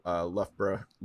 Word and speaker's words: Loughborough: So, you know, UCL, University of Loughborough, as you would say. – Well done on your pronunciation there. it's a hard one.